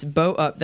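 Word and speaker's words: Beaux Up: Bow Up. – Yes. – Is that the correct pronunciation? – Yes. Bow Up. That's BEAUX.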